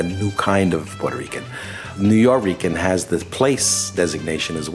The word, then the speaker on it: Nuyorican: N-U-Y-O-R-I-C-A-N has become the established way of spelling it. So you have an almost phonetically Spanish way of saying New York-Rican.